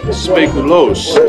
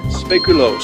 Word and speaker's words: speculoos: Now, how do you say that again? – Speculoos. – Speculoos. – That's how you pronounce it in Dutch.